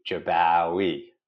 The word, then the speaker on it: Jabbawy: My mom used to educate people. It's Wowee, Jabbawy.